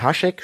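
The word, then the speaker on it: Hašek: Ich würde sagen, Hasek spricht man das aus, weil das ist so ein S mit so einem Apostroph. Und ja, das ist, ähm, das gibt's so eine Buchverfilmung.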